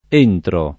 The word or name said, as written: entrare